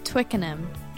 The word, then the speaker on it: Twickenham: It's Twickenham.